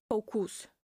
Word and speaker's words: focus: But if you want to make sure that you get the right pronunciation and you feel confident saying it, make sure that the last syllable you pronounce it with a schwa sound, focus.